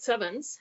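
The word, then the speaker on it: CEvNS: And this is what we pronounce it sevens. It's coherent elastic neutrino nucleus scattering, and the V is the neutrino. It's a Greek letter nu that we pronounce it V.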